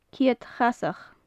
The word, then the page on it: ceud chasach: gaelic